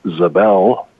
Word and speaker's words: Zabel: And if you're interested in the other side of me, because we are all multidimensional beings, that's Mel Zabel, same last name, different pronunciation, .com, MELZABEL.